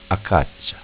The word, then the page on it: Acaccia: Dialetto